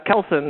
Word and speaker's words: Kelson: Kelson speaking. What can I get for you? – You say your name's Calcium? – Kelson, that's K-E-L-S-O-N.